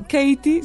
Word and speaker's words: Katie: Bueno, Katie se escribe K-A-T-I-E.